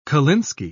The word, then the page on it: kolinsky: British English